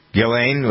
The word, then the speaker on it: Ghislaine: Ghislaine, without that, the S is silent.